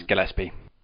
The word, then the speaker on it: Gillespie: Is it Gillespie or Gillespie? – Yeah, it's spelled unusually, but it's Gillespie.